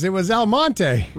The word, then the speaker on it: Almonte: I figured out what Elmont was. Exclamation point, exclamation point. – Elmonte? – It was Elmonte. – Really? – Oh. Yeah. So it's spelled Almonte.